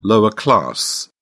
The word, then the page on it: lower class: British English